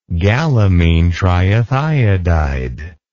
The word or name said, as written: gallamine triethiodide